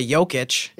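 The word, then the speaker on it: Jokić: I mean, we all knew that LeBron James and Nik- Nikola Jokovic, Jok, Nikola Jokic.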